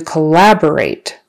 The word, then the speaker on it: collaborate: Let's move on to collaborate. Collaborate. Emphasis on the second syllable.